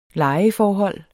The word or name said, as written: lejeforhold